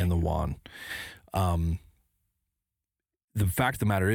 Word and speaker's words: yuan: dollar, to move into their own currency, or replace it with the yuan.